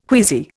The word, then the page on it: queasy: Indian